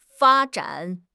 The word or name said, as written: fa1 zhan3